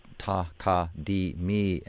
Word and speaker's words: Takadimi: Takadimi are actually four syllables, Takadimi, and spelled, I mean, the way they're spelled in the project, they're all capitalized.